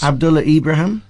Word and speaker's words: Abdullah Ibrahim: Abdullah Ibrahim.